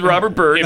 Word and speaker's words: Robert Byrd: There he is, holding hands with Robert Byrd.